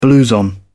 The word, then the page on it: blouson: British English